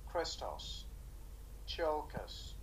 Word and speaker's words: Christos Tsiolkas: It was co-written by Christos Tsiolkas. – I think you did actually get the pronunciation pretty close.